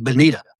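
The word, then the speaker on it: Bonito: It is an Atlantic Bonita, even though it does have an O on the end. That's just the way we say it.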